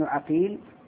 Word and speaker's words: عقيل: فابن عقيل بالفتح ثم بكسر القاف هذا نيسابوري، وأما ابن عقيل فهو فريابي.